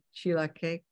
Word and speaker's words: chilaquiles: Chilaquiles. Chilaquiles.